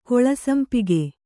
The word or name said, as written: ಗೋಳಸಂಪಿಗೆ